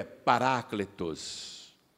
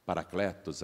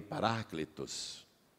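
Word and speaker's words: parácletos (παράκλητος): Mas quando os estudiosos da língua grega leram o original e dissecaram esta palavra, a palavra em grego é parácletos. Nós falamos paracletos aqui no Brasil, mas em grego a pronúncia mais aproximada é essa que eu te falei, parácletos.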